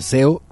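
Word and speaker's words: Céu: Estoy hablando de Zeo, se escribe CEU, C-E-U, se pronuncia Zeo y quiere decir cielo.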